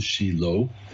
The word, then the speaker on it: Shiloh: This is where I live, in Shiloh, and we call it in Hebrew Shiloh, that's the actual Hebrew pronunciation.